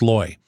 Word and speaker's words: Looy: His last name is spelled L-O-O-Y, but it's pronounced Loy.